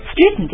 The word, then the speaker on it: straighten: 但是呢你也只要多来多念几遍 straighten oh I know why it's so strange because here in English we call this a glottal stop you have T and N next to each other we just eat it we so straighten just like mountain、right?